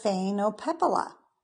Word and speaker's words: Phainopepla: Okay, so I have the Phainopepla. I say that right? – Yes, okay. Perfect.